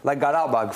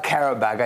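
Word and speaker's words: Qarabağ: So obviously you look at broadcasts from the league, but then there's like Garabag, for example. It's not Karabag. I looked up how to pronounce Garabag specifically.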